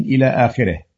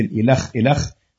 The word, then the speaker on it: إلخ: ثلاثة، عند قراءة هذه الاختصارات ننطقها غير مختصرة، فنقرأ إلخ قائلين إلى آخره، وصاد باء قائلين صندوق بريد، ومل قائلين مليلتر، ولا نلفظها باختصارها، وما يقوله بعض الناس من مثل إلخ إلخ خطأ ينبغي تجنبه.